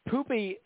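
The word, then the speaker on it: Poupée: I guess she was born in San Diego, but Poopy, P-O-U-P-E-E, and there's a little line over it, but it's pronounced Poopy. I guess in France, in French, it means doll.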